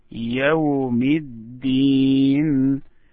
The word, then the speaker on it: يوم الدين: Now we want to stress on the last word. يوم الدين. The first one you did it in a good way. مالكي يومي. But the last one, يوم الدين. يوم الدين.